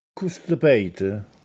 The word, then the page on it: Accustepète: Castellanetano